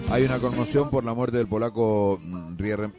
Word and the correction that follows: Riemersma: Se pronuncia en realidad, lo voy a describir así para que no hace. Riemersma, ahí está.